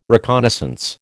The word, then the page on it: reconnaissance: American English